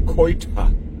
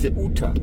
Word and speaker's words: Ceuta: Zunächst mal habe ich den Ort falsch ausgesprochen. Ich sagte Ceuta. Das heißt natürlich Ceuta.